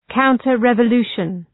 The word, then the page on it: counter revolution: ,kaʋntər,revə’lu:ʃən